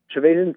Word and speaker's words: Trevathan: You know, you have Danny Trevathan. That's how you say his name? Trevathan? – Yeah, Trevathan, yep.